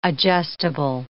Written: adjustable

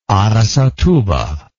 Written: aracatuba

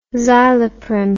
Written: Zyloprim

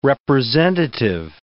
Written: representative

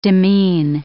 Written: demean

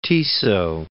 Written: Tiso

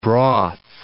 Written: broth

